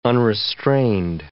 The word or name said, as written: unrestrained